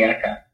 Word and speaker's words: Arkar: I was just trying to think how to say your name. Linn Let, is it Arkar? Arkar or Arkar? – Yeah, you can call me Arkar.